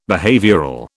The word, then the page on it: behavioural: British English